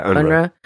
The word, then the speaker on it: UNRWA: Yeah, UNRWA.